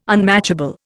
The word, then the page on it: unmatchable: Indian